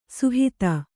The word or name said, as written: ಸುಹಿತ